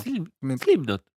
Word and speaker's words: Slipknot: Slipknot.